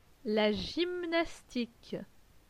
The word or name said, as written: La gymnastique